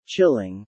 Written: Chilling

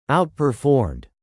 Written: outperformed

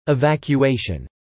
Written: evacuation

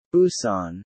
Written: Busan

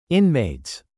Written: inmates

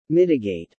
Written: mitigate

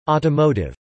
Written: Automotive

